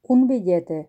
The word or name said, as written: un billete